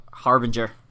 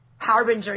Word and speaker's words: Harbinger: Harbinger. – Harbinger, yeah, I'm always going to say it wrong.